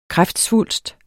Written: kræftsvulst

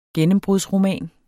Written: gennembrudsroman

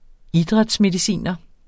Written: idrætsmediciner

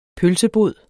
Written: pølsebod

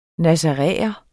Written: nazaræer

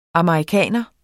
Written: amerikaner

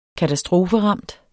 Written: KATASTROFERAMT

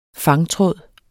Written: fangtråd

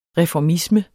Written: reformisme

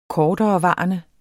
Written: korterevarende

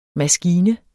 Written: maskine

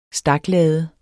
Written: staklade

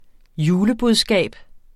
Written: julebudskab

